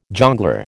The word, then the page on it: jongleur: American English